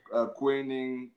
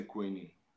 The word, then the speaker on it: Kwaning: Some say Kwaning, Kwaning. I would say Kwaning.